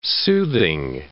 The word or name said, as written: soothing